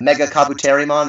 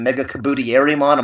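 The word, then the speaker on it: MegaKabuterimon: They mispronounced Mega Kabuterimon. They called him Mega Kabutierimon. I'm like, that's wrong.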